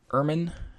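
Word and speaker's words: Ermine: Ubuntu 19.10, Eon Ermin, I'm not sure if it's Eon or not, but it's definitely Ermin is how you say the name of the animal.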